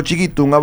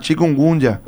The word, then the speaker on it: chikungunya: Yo que le digo chiquitunga porque me cuesta pronunciar, ¿verdad? Chikungunya.